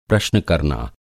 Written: प्रश्न

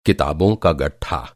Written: गट्ठा

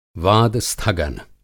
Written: वाद-स्थगन

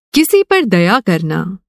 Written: दया

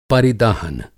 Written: पर

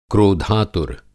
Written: क्रोधातुर

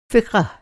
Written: फ़िक़ह

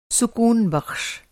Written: सुकूनबख़्श

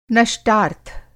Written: नष्टार्थ

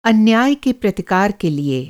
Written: प्रतिकार

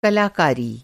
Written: कलाकारी

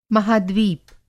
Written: महाद्वीप

